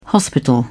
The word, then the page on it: hospital: British English